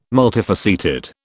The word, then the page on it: multifaceted: British English